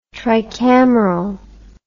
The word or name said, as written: tricameral